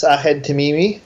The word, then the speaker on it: Ahed Tamimi: Ahed Tamimi.